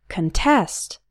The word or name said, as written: contest